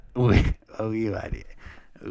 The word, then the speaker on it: Ouida: O U I D A.